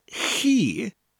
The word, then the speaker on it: chi: And chi sounds very similar to the word key, but that CH sound, because it's spelt CHI, chi, sounds like the end of the word loch, as in Loch Ness. So say the word key, but with that CH sound in front of it.